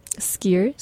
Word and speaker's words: skyr: And how do you say S-K-Y-R? – Skyr.